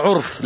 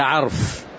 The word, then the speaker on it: عرف: بعض الطلاب يقرأوها عرف، لا، ليس عرف، العرف المعروف، إنما هي تنطق هكذا عرف، يعني من طلب العلم ليصيب به عرض من أعراض الدنيا لم يجد عرف الجنة، أي ماذا؟